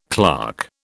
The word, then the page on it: clerk: British English